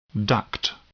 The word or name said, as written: duct